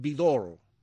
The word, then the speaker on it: Vidor: Charles Vidor nació, se pronuncia así, señor Teudia. Mañana viene Roxana, pero hoy es miércoles. El gran director de cine de origen húngaro, Charles Vidor. ¿Vidor? Dice que sí.